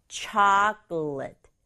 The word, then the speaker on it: chocolate: chocolate. chocolate. 예요. 우리말로는 초콜릿, 하지만 영어는 앞에 강조줘서 chocolate. chocolate. 이렇게 발음해요.